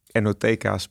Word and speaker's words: NOTK: Maar er waren al meerdere enoteca's bij de KVK geregistreerd. En toen bij het beetje sparren over het ontwerp van het logo ontstond het idee van NOTK. Op zijn Nederlands spreek je uit als Enoteca.